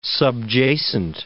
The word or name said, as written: subjacent